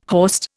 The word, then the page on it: horst: Indian